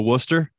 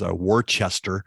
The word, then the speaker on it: Worcester: Head on out to Worcester. Or for, as Eric would say, since he pronounces all of the letters, Worcester.